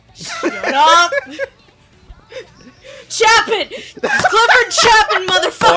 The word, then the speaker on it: Chapin: For the longest ass time, I mispronounced Clifford Chapin's last name, because my thought process was Charlie Chaplin. I was like, it has to be similar, right? No.